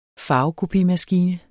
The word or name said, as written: farvekopimaskine